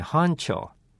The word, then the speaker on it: honcho: 実はですね、もともとは日本語から起こった表現で、聞こえましたかね、班長という発音になっていますが、スペリングは H-O-N-C-H-O 本庁と書きます。